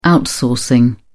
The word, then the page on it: outsourcing: British English